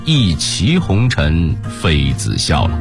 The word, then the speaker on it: 一骑: 再比如说杜牧写杨玉环吃荔枝的经典诗句，一骑红尘妃子笑，哎，咱们以前都是这么读的对吧？然而在最新版的现代汉语词典中呢，这个骑字只有一个读音了，就是骑，坐骑也变成了坐骑，这句诗呢就成了一骑红尘妃子笑了。